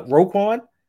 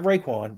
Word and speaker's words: Roquan: No, Roquan. Not Raekwon. Roquan. This ain't the Wu-Tang. – Did I say it wrong? Roquan? – You said Raekwon. It's Roquan.